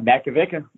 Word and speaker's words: Makovicka: But yeah, Makovicka, that's how you say it.